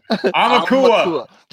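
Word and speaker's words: Almakua (ʻAumakua): Almakua. – Just think like owl. Like owl, because it's AU and that's owl in Hawaiian. So Almakua. Almakua.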